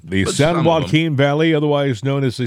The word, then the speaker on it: San Joaquin: San Joaquin Valley. That's how it's pronounced. – No, it's not. It's San Joaquin and that's it.